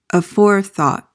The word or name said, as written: aforethought